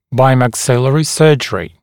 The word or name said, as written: bimaxillary surgery